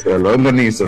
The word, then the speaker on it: Longaniza: We're in Brooklyn, where brisket and lasagna. Am I saying that right? Help me, man. – Longaniza, Freddy. Oh, see, I got it all messed up.